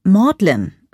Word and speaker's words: Magdalen: Das College, dem ich angehören werde, ist das Magdalen, das seltsamerweise Magdalen ausgesprochen wird.